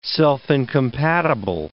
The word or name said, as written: self-incompatible